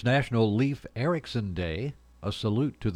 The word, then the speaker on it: Leif: It's pronounced Leif, by the way, like safe.